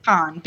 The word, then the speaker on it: Cannes: Is that how you say it? Cannes? – It's Cannes. Be cultured. – Cannes. I am very smart, and I know things of the world. – It's con, yeah.